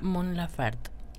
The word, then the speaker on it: Mon Laferte: Mon Laferte.